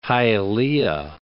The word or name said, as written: Hialeah